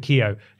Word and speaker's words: Keogh: It's a tweet from video game industry and culture researcher Brendan, how would you pronounce that? K-E-O-G-H? – K-E-O. – Keo. – Keo. There was actually someone at my school whose surname was Keo.